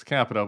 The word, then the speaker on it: Capito: It's Capito, but you can mispronounce her name.